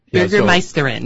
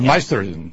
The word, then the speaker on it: Bürgermeisterin: Bürgermeisterin, meaning female. – Bürgermeisterin. – Yeah, right. – Bürgermeisterin. – Bürgermeister is male. – The gates, Bürgermeisterin.